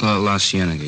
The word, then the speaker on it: La Cienega: Over on, that street out that way, La Cienega. Is that how you say it? – La Cienega, yeah.